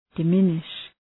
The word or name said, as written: diminish